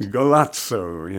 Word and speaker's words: Golazo: Taking a bit of a broader look, there's Andreas Campomar's Golazo, is that how you pronounce it? – Golazo, yes, which means, wow, what a goal!